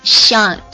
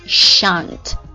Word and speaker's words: shan't: shall 的话，同样它的肯定式就是 shall do, somebody shall do。然后它的一个否定式就是 shall not do，然后这个 shall not 它等于 shan't shan't，注意一下这个它的发音呢，缩写形式。